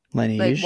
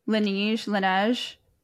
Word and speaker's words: Laneige: How have you tried the Langine? Is that how you say it? – Laneige. – Like Waterbank. Laneige.